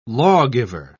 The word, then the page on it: lawgiver: British English